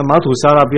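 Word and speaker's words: 玛土撒拉: 那马土沙拉不要读成马示沙拉了。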